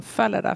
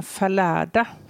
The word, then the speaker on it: Fallada: han ville absolut att det skulle uttalas som Falada, för det läste om en trumpetstöt medan Falada, beroningen på andra stavningen, det lät som någon som snubblade ut för en trappa och det ville han inte.